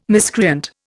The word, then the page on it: miscreant: Indian